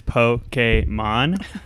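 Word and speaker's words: Pokémon: My weekend geek is about three syllables. It is Pokemon.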